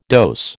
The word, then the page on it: dose: American English